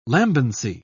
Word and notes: lambency: British English

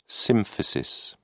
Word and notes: symphysis: British English

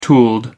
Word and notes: tooled: American English

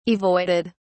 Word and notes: avoided: British English

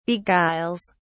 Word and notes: beguiles: British English

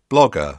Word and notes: blogger: British English

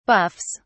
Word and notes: buffs: British English

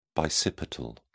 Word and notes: bicipital: British English

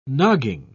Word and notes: nogging: British English

